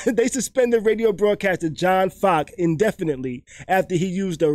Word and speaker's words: Focke: The Charlotte Hornets suspended radio broadcaster John Faki Focky. Oh my gosh. Okay. – How you spell it? How you spell it? – F-O-C-K-E. Focky. – I think that's Fock. – Fock. Just Fock? – Yeah.